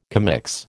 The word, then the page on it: commix: American English